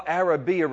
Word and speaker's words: Arabiya: Am I pronouncing that right? Arabiya. Arabiya, okay.